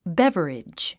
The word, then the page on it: beverage: bév ə ridʒ; ベヴァリジ